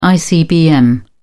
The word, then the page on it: icbm: British English